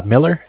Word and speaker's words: Miller: Miller, M-I-L-L-E-R.